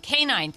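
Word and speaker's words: K'naan: Kanan Kadbi Wassam, also known as, I think I'm saying this right, Kanan? – Kanan.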